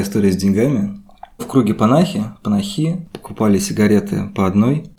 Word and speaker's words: Панахи: Ну, хорошо, что мы сегодня обсуждаем панахи или панахи. – Сейчас да. – перестроиться. – У Наверное, меня я все время информацию. сбиваюсь на. Ну, ударение, по идее, на последний слог должно быть.